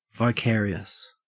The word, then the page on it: vicarious: Australian English